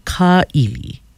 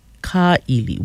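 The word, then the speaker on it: Ka'ili (Kaʻili): But the one that I'm looking at is spelled K-A with the kak over the A, Okina I-L-I. So you would pronounce it Ka'ili. Ka'ili, which means to snatch, to grab, to take by force, to seize, abduct, usurp, to gasp.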